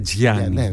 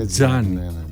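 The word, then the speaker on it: Gianni: Δεν είναι Τζιάνι. Είναι Τζάνι. – Είναι Τζάνι.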